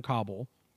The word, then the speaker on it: Kauble: So apparently this woman named Debbie Jordan Cobble, I think is how you pronounce it. – Debbie Jordan. K-A-U-B-L-E.